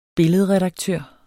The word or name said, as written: billedredaktør